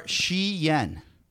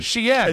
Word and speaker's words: Cheyenne: You are Shi Yen. – What's Cheyenne? – Oh, that's his pronunciation Cheyenne. – Of Cheyenne. Cheyenne.